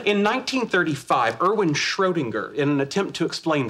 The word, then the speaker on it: Schrödinger: Y ahí está, así como se pronuncia Schrödinger. Schrödinger.